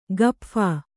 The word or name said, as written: ಗಪ್ಫಾ